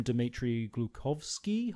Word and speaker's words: Dmitry Glukhovsky: From Dmitry Glukhovsky.